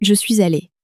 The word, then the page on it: je suis allé: French